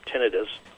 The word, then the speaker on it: tinnitus: It's one of the largest categories of disabilities that affect military veterans, and that's hearing loss and also a related disability of tinnitus, or as some pronounce it, tinnitus.